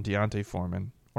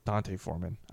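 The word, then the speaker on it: D'Onta Foreman: So a huge, massive question mark on Deontay Foreman. Or I think it's Dante Foreman. I may have been saying it wrong this whole time.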